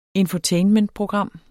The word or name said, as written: infotainmentprogram